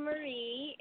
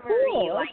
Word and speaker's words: Marie: This is Dani Marie, and I'm calling from Los Angeles, California.